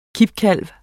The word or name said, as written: kippekalv